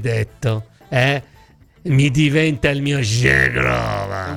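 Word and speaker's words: Zhegrova: Si pronuncia Zegrova, non Zegrova. Una Z seguita da un H nella lingua albanese si pronuncia sempre comunque J.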